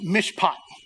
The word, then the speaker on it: mishpat: The Hebrew word for justice here is this funny word that's pronounced mishpat. Say that, mishpat.